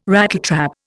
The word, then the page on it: Rattletrap: Indian